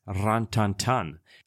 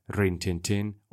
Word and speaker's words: Rin Tin Tin: Actually, the presenters of the Rest is History podcast opened my eyes to the fact that the dog's name is properly pronounced Ran Tan Tan, or Ran Tan Tan, sorry for my French, as that would be a correct French version.